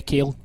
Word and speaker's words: Kalle: I'm not going to pronounce this right, but Kale? – Yeah, Kale. – K-A-L-L-E 1009.